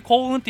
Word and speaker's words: 耕耘: それに、伝えるの右側伝説の伝の右側カタカナで 2 って書いてカタカナの無って書くやつねこれで幸運って読むんやえー、